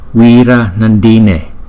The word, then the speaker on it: Wira Nandine: Tinh tấn hỷ Phật. Chúng ta sẽ phát âm là Wira Nandine, Wira Nandine, Wira Nandine, Wira Nandine.